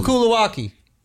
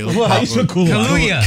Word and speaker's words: Kaluuya: Daniel Kulawaki. Look up Daniel Kulawaki. – Just put Daniel K. – Kaluuya.